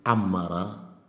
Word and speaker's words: Amara: That is a very, very correctly Amara.